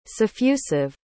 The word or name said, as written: suffusive